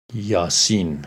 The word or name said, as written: Ya-Sin